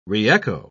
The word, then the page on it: re-echo: British English